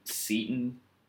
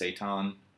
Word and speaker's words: seitan: But no, so we learned about tempeh and veganase or veganase, as people call it, and um, seton is what we say, but I think it's pronounced seitan.